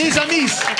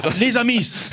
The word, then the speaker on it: Amis: Les Amis. – Ja. Auf Französisch, der ist den S nicht prononcieren. Ja, ja. Nun ist es mal der Spruch, der Film muss man. – Les Amis.